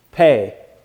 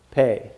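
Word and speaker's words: Pe: Pei, right here. So that's how it's pronounced, Pei, just like Pei, like I wrote it. It's actually P-E, Pei.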